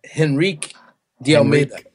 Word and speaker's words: Henrique de Almeida: So, it's Henrique with a very short E on the end, and De Almeida.